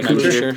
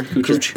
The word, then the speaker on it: Kuchar: Yeah, Kuchar. – Kuchar.